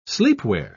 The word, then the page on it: sleepwear: British English